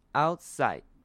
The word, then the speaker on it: outside: 注意看到它中间有一个 T，但是这个 T 其实可以轻轻的带过，会变成 outside，所以其实这个 T 没有念出来，念得很清楚，没有念成 outside，会变成 outside。